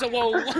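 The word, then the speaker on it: Zoro: His name's Zolo. His name's Zolo. They say it how it's spelled in Japanese.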